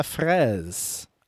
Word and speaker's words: fraise: How do you say strawberry? Is it fraise? – Because there's an E at the end, right? – Yes. So, ramener ta fraise.